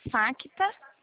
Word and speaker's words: Facta: É FA, o C é mudo, tá? Facta.